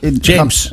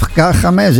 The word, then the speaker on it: James: hoe spreek je dat? Is dat James. – James. Of Games. Ja, ik zat te twijfelen. – James. – Oké, dat zal wel. – Oh, James.